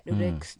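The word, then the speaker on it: LULU X: あの、ルル X、 LULU スペース X で、ルル X と読む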